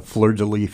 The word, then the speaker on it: Fleur de Lis: Which is, Flurry. – Well, it's uh, Fleur de Lis Forge, actually.